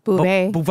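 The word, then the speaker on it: Beauvais: How do you say her last name? Bouvot? – Bouvet. Bouvet. It's French.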